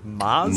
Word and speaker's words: Mos: Moz? – Moz speed run. – Okay, Oz. – And audience, please keep in mind this is spelled M-O-S. – Yeah, but apparently you say Moz.